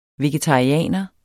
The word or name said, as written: VEGETARIANERE